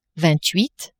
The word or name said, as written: vingt huit